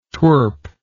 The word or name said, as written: twirp